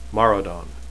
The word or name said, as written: maradon